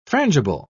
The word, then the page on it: frangible: British English